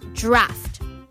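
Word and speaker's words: draught: So for the British, it's spelt D-R-A-U-G-H-T. D-R-A-U-G-H-T. Draft. Even though it's spelt with a G-H-T, it's pronounced draft. And the American standard, it's spelt D-R-A-U-G-H-T. F-T. So the British is with G-H-T, D-R-A-U-G-H-T.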